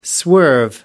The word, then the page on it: swerve: American English